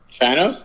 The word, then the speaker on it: Thanos: Um, how do you pronounce the bad guy from Infinity Wars? – It's Thanos.